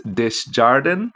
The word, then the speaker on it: Desjardin: The gym teacher's name is pronounced Miss Desjardin. I believe it is French.